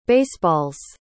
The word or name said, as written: baseball's